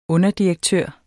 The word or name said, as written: underdirektør